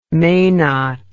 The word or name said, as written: menat